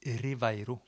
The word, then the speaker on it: Ribeiro: så sier det jo V for når det står B. Og som området eh, som ligger eh, inne i Galicia, som heter Ribeiro, det uttales Riveiro.